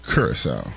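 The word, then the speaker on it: Curaçao: This is how you say curacao. – Good. – Curacao. – Okay, curacao. There you go. Ha! It's not very often that happens. All right. When we get back, we'll get... – The locals pronounce it curacao.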